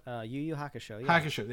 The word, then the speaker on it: Yu Yu Hakusho: Yu Yu Hakusho. – Yu Yu Hakusho.